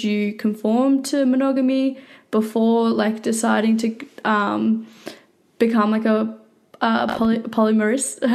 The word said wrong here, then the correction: polyamorous: Sorry, polyamorous person.